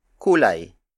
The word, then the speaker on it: kulay: First, the Tagalog word for color is kulay.